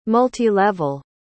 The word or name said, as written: multilevel